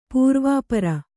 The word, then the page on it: ಪೂರ್ವಾಪರ: pūrvāpara